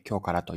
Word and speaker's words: 驚辛: 京からというのは、驚くに辛いと書いて京からと読むんですが、辛いものを扱っているお店です。